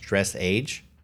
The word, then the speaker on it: dressage: You got it right that last time. It's dressage. – Dressage.